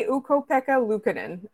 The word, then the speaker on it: Ukko-Pekka Luukkonen: As there were rumors of the Habs getting Devon Levi, or I'm going to say Ukko-Pekka Luukkonen, and I hope that I pronounced that correctly.